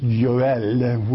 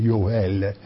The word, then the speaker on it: Yoel: Joel in the Hebrew is pronounced Yoel, which is Yahweh's is God. I'll say it again, Yoel, which is to say Yahweh, and El, which is to say Yahweh is El, or God.